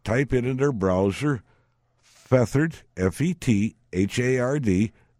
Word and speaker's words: Fethard: Now, that's not part of a pillow. That's not feathers. – Right. – It's Feathered is a town. It's spelled F-E-T-H-A-R-D, Feathered Tipperary in Ireland.